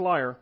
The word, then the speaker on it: lyre: Let our Lord command your servants here in your presence to look for someone who knows how to play the lyre. That sounds funny to me, but I looked up the pronunciation. It is lyre.